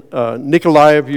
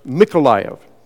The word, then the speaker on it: Mykolaiv: And the church also in Nikolaev, Ukraine, or in Ukrainian language, it is pronounced Mykolaiv with the M sound. So that is the correct Ukrainian pronunciation,